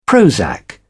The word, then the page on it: Prozac: British English